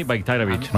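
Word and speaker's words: Bajraktarević: O sea, la J la conviertes en una I, Bayraktarevic.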